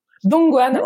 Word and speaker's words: Dongguan: Dongguan, ouais, c'est ça.